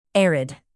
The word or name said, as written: arid